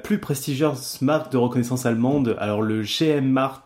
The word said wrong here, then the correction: Geheimrat: je ne sais pas comment on prononce ça, il insistait toujours pour se faire appeler par ce titre. Et du coup... – Geheimrat. – Ouais, bon voilà, ce qu'a dit Alan, quoi.